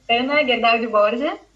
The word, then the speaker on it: Ana Gerdau de Borges: So the way you pronounce it in Portuguese is Ana Gerdau de Borges.